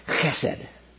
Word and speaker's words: chesed: I remember it from seminary because it's you say chesed. And you have to put a at the front of it, and I just think that's kind of fun.